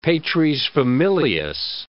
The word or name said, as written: patresfamilias